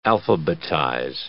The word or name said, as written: alphabetize